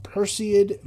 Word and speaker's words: Perseid: The Perseid meteor shower. I'm not the best at pronouncing it, so it's kind of hard for me to pronounce it correctly. – But you did a good job. Perseid meteor shower.